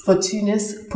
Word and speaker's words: Fortunice: Fortunis Property.